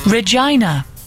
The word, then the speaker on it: Regina: I was listening to Dear Scott, and there was someone from my neck of Canada, and the posh Radio 2 lady pronounced the place wrong. Regina. It rhymes with a lady's girly bits.